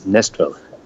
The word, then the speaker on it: Næstved: And actually, it's called Næstved. And if you're not from here, if you're not from Denmark, you would have a problem trying to pronounce that. But it's Næstved.